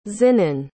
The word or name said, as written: zinan